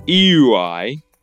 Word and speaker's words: euoi: is the word EUI, which I'm pronouncing like that, and is spelt E-U-O-I. In other words, absolutely zero consonants in it.